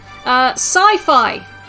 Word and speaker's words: Psyphi: Sci-fi. And it's spelt P-S-Y-P-H-I.